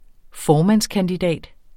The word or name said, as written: formandskandidat